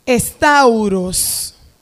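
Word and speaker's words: stauros: La cruz, la palabra griega, se pronuncia estauros. Se escribe S-T-A-U-R-O-S. Y significa palo o estaca vertical.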